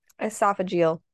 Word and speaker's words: esophageal: An anonymous call to the police said that he was poisoned, but Anna said it was, I'm not going to say this right, esophageal cancer, like your esophagus. – Esophageal. – Okay, that's it.